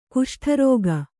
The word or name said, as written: ಕುಷ್ಠರೋಗಿ